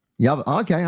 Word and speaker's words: Yavapai: Yavapai? – Yavapai.